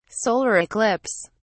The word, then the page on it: solar eclipse: British English